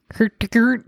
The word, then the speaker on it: Hurtigruten: Hurtigruten.